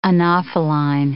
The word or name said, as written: anopheline